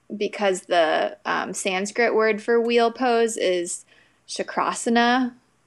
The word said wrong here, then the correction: Chakrasana: And so chakra, sorry, I said that wrong. Chakrasana.